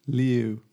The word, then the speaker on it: Liu: How do we pronounce his surname? Liu, I think. – Liu. – Yes, yeah. Simu Liu.